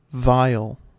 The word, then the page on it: vial: American English